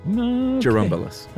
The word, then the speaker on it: Churumbelas: In fact, there is a Spanish folk song by the Churumbelas, suggestion on how to pronounce it, Churumbelas. – Well, is it right. A DJ? – It's a CH pronounced like a JU, he says. Jerumbulus.